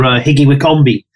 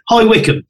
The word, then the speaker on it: High Wycombe: Remember, there was once a chap phoned up for a quote for Higgy Wickombe. So where is he? Was it Higgy Wickombe? Oh, High Wycombe was a Higgy Wickombe.